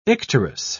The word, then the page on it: icterus: British English